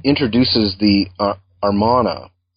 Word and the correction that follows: Amarna: or perhaps I'm not pronouncing that right. – No, that's right. The Amarna archives, yeah, Amarna letters.